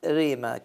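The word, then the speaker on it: ريما (ῥῆμα): هي باليوناني تنطق ريما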